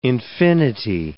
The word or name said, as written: infinity